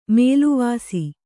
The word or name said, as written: ಮೇಲ್ವಾಸು